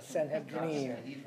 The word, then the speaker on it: Sanhedrin: That's how it's said, yeah, Sanhedrin. Yeah. It's, yeah. And the I is always an E, the Sanhedrin.